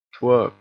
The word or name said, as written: twirp